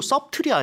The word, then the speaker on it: subtle: 그리고 subtle이 아니네요, 발음이. – Yeah, that's right. The spelling is S-U-B-T-L-E. But we almost don't hear the B, so it's right.